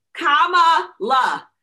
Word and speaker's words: Kamala: And y'all get her name right because everybody's jacking it up still. Kamala.